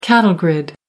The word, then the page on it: cattle grid: American English